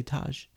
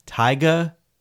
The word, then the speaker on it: Tyga: It's T-Y-G-A. – Tyga. – Oh, I was going to say Taj. – No, okay. Tyga. – Tyga. I have no clue who that is. It is Tyga versus Busta Rhymes.